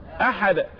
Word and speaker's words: أحد: اذا هنا الدال ساكنة مش مشددة. الحد يبقى الحدة. انما قل هو الله احد سبحانه وتعالى. قل هو الله احد. يبقى قل هو الله احد احد احد.